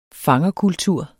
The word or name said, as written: fangerkultur